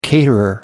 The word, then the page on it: caterer: American English